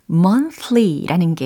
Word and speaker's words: monthly: 어, month 이거 먼저 완성을 해보시고 뒤에다가 ly라는 것을 붙이시면 됩니다. monthly, monthly, monthly, monthly 아니에요. month 이렇게 th 발음이니까 monthly.